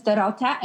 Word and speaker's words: Dorota: Thank you for having me name is Dorota, and it's awesome because you didn't pronounce it Doritos, so we're doing good there.